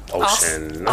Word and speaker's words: Oisin: Like, they're spelled one way and they're pronounced a whole different way. Like, for example, the only one I can really remember off the top of my head, it's O. I think it's spelled O-S-I... N or O-I-S-I-N. It's weird spellings. – O-S. – And it's like ocean or some shit like that. I don't know.